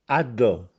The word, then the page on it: Addò: Castellanetano